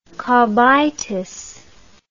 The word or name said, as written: cobitis